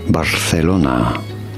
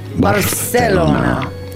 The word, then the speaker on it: Barcelona: You do want to pronounce the C a little bit like TH in English with your tongue between your teeth. Barcelona. – Barcelona. – Barcelona is how it is said in Spain.